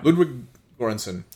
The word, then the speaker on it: Ludwig Göransson: Ludwig Göransson.